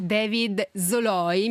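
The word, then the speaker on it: David Szalay: Un consiglio di lettura che mi sento di dare a tutti è Nella carne di David Zoloi, mi hanno scritto da Delphi che si pronuncia così, è molto difficile da pronunciare, l'ho trovato in molti modi diversi, questa dovrebbe essere quella ufficiale.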